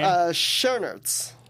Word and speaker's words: Schoenaerts: Schoenaerts.